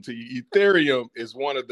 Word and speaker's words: Ethereum: Excuse me if I pronounced this wrong. Ethereum. – Ethereum.